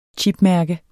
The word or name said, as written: chipmærke